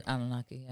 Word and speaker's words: Anunnaki: Like, those and then, like, some, like, leftovers of the Anunnaki. – Yeah. – Did I pronounce that right? – Anunnaki, yeah.